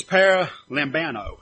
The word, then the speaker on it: paralambano: This word is, and if I pronounce this right, it's paralambano. It's P-A-R-A-L-A-M-B-A-N-O.